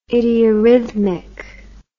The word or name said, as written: idiorrhythmic